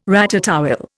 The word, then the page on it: ratatouille: Indian